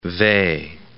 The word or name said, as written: Weil